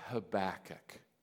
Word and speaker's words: Habakkuk: The book of Habakkuk, right? That's how I pronounce it. I've heard various pronunciations. Habakkuk, three chapters long.